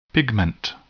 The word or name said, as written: pigment